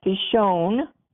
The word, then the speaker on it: Pishon: The first river that branched off is Pison, pronounced Pishon, which means dispersive, spread out, act proudly.